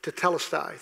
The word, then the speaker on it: Tetelestai: Tetelestai.